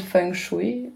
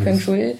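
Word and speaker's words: feng shui (风水): Okay, I think most Westerners pronounce it feng shui or feng shui. – Feng shui. – Yes. – And Chinese it would be feng shui.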